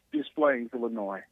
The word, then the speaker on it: Des Plaines: Let me get out of Duplain. Am I pronouncing it right? Duplain, Illinois? – Des Plaines, Illinois.